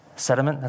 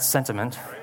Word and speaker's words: sediment: Sediment, that's how you say it, right? Not sentiment, sediment.